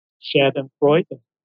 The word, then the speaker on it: Schadenfreude: Schadenfreude. Schadenfreude. It's a German word.